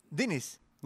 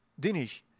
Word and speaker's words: Diniz: For, for once and for all, is it Luciana Diniz or do I have to adapt to your and your pronunciation of Diniz? No, Diniz. Diniz. – Luciana Diniz. Dinish. Yeah. Dinish. – Put an SH on everything.